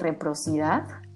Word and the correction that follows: reciprocidad: reciprocidad nunca se Reciprocidad.